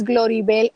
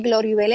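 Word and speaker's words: Gloribell: Mi nombre es Gloribel Ortiz y Gloribel es, ¿verdad?, se escribe con B de bueno, E-L-L. Tengo que decir que es con dos L, ¿verdad?, porque si no, no recibo el correo electrónico.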